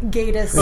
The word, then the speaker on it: Gatiss: I think the Brits say Gatiss. – Oh, Gatiss. – It's Mark Gatiss.